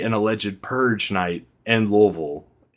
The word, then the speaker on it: Louisville: I think it was right after the first one, but in Louisville, and I know I'm saying Louisville, it's Louisville. That's how they say it down in Kentucky.